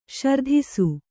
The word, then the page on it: ಸುರತಿಸು: suratisu